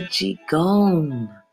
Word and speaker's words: Qigong: I'm gonna spell it for you. It's spelled Q-I-G-O-N-G. Qigong. That's right, Qigong.